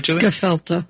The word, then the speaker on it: gefilte: in the book that comes with the Great Courses Plus series, you have a recipe for gelfite fish. Is that, I don't know if I'm pronouncing that correctly. – Gefilte.